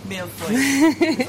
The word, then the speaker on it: millefeuille: It looks like a millefleur. – It does. – Am I saying it right? – Millefeuille. – Obviously, my French is really bad there. – It's slightly different.